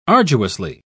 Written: arduously

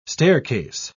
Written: staircase

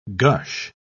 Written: gush

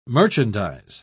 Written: merchandise

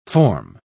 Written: form

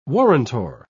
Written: warrantor

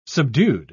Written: subdued

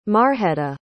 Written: marjeta

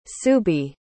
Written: suubi

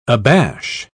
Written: abash

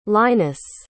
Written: linus

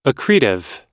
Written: accretive

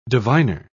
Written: diviner

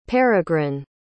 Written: Peregrin

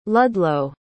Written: ludlow

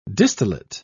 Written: distillate

